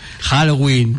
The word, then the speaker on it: Halloween: Halloween, vale.